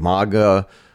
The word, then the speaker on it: manga: Well, MAGA is a mispronunciation on your part. Okay. MAGA is Make America Great Again. – And oh, okay. – Manga is a Japanese, it's a style of anime.